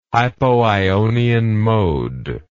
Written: hypoionian mode

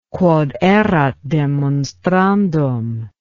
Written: quod erat demonstrandum

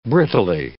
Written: brittlely

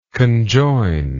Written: conjoiner

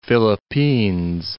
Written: Philippines

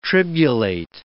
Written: tribulate

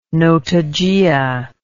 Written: Notogaea

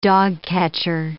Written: dogcatcher

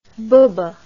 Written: bubbe

